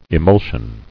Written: Emulsion

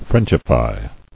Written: Frenchify

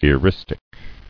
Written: Eristic

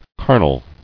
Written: Carnally

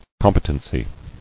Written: Competency

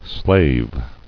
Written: Slaving

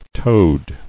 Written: Toed

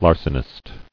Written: Larcener

